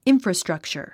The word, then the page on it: infrastructure: ínfrəstrʌ̀ktʃər; インフラストラクチャァ